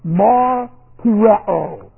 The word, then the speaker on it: martureo: Aorist active indicative of martureo. Transliterated. Martureo. Long O.